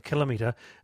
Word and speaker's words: kilometre: I agree with Allan, but he's wrong about kilometre. The ometers, a gasometer, odometer and other things. – No. – Kilometre, kilogram, kiloton and other thousands are pronounced alike, says Ken.